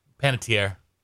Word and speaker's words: Panettiere: Is that how you say it? – Panettiere.